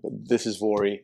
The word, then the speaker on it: Vuori: I'm so glad that you've pronounced that in the way that I've always questioned how it's supposed to be pronounced. So Vuori. – Vuori. Yeah, it's a tricky one.